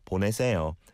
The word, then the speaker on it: 보내세요: 보내세요. It's four syllables there.